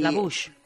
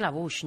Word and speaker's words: La Bouche: Y uno de los grupos que más me gustan de esa época, Le Bouch. Creo que se pronuncia así. Y La Bouch. – La Bouch. – Yo creo que La Bouch, ¿no? Que es que en francés.